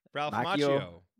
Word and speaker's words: Ralph Macchio: Is it Ralph Macchio? – Ralph Macchio.